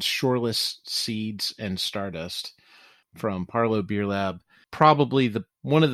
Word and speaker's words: Parleaux: Parlo Beer Lab. It's P-A-R-L-E-A-U-X. – Because it's New Orleans. Yep. – That's right. Of course, it's spelled L-E-A-U-X.